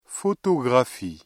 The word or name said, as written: photographie